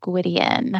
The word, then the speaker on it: Gwydion: Is that how you pronounce it? – I think so. I think that's how I pronounced it. I feel like my brain changes it on me every now and again. That sounded right. – Gwydion. Gwydion. – Yeah, first one's good.